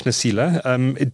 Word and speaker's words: Nasila: Mark Nasila, Dr. and Professor Mark Nasila, I believe.